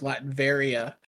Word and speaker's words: Latveria: Just to clarify, while you were recapping, I looked up Latveria, and I do believe it is pronounced Latveria.